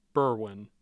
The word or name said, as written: berwyn